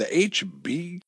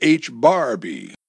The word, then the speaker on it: H-Bar-B: "The HB?" Tracy repeated. "It's not the HB," Amy said, showing off her newly acquired knowledge, courtesy of Jerry. "You pronounce the bar. Get it? It's a bar. The H-bar-B."